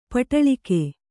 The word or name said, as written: ಪಡಲಗೆ